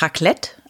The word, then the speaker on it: Raclette: Ja, ja und äh, ich habe jetzt auch erfahren, das heißt eigentlich Raclette, also mit Betonung auf dem zweiten.